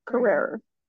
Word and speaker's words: Carrere: Is it Tia Carrere? – Carrere.